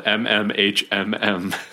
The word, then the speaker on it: mmhmm: but it's spelled M-M-H-M-M, so it's just pronounced mm-hmm, which is...